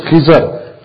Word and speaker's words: Khidr: We usually pronounce it Khidr alayhi salam, and it's famous that his du'as are readily accepted, mustajabud da'wat.